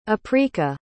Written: aprika

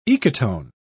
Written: ecotone

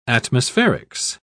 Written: atmospherics

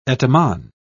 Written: etymon

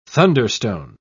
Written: thunderstone

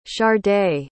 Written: sharday

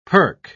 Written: perk

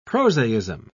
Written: prosaism

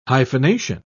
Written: hyphenation

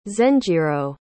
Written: zenjiro

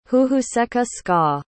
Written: huhuseca-ska

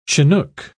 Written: chinook

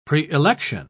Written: preelection

